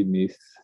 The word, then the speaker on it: Meath: So you grew up in Ireland, which hopefully I pronounced the county Meath. – Mm-hmm. That's pretty good. Not too bad.